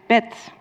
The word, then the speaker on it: bed: So the word bed, which means bed, which means bed in Dutch, we pronounce it bed. Why? Because it's at the end of the word and it loses the voicing.